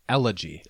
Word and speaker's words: elegy: Okay, so I looked up the pronunciation on Google, mm-hmm. and it's elegy.